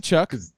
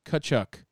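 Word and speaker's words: Tkachuk: No, it's, it's Kachuk. – Chuck. Okay. – No, it's no I, I no mean, T. He played no T. Kachuk.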